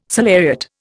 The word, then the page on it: salariat: Indian